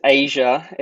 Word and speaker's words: Aijia: So the name is Aijia. It's pronounced like the continent, but it's Aijia.